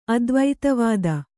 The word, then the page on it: ಅದ್ವೈತವಾದ: aDvaitavāda